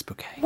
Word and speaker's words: Bucket: And then Richard sort of proffers that it's spelled B-U-C-K-E-T. – Oh, bucket. Bucket. – It's bouquet. – What was the name again, please? – Bouquet. – That's spelled B-U-C-K-E-T. – Oh, bucket. Oh, yes, we have a bucket. – Pronounced bouquet, dear.